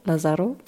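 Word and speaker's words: Lázaro: Paola Li Lazar Lazaro, Lazaro, wieder falsch ausgesprochen, danke, ja.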